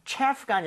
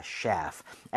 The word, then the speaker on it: chef: 주방장, 요리사라고 얘기를 할때 셰프가 아니라 셰프. – chef입니다. chef. f는 입술 안 붙는다고 했으니까 셰프 하시면 안 되겠죠? – 네. – so chef 해서 발음이 나와야 됩니다.